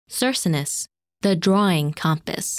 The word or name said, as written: Circinus